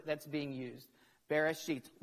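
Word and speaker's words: Bereshit (בראשית): First of all, there's a word pronounced Bereshit. It's not a bad word. Bereshit. It's a Hebrew word, and it literally means what is first.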